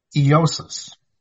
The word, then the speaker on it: Joses: Well, as you might have guessed from our title, we're interested in this certain disciple whose name was Joses, which apparently is pronounced Eosis.